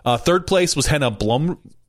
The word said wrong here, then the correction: Henna Blumroos: Wait, let's say it right. Henna Blumroos. Blumroos. That's what we're going with.